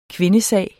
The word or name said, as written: kvindesag